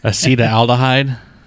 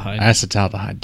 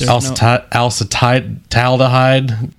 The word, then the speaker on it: Acetaldehyde: Acetaldehyde. Did I say that right? – Acetaldehyde. – Dang it. Okay, I was close. Acetaldehyde.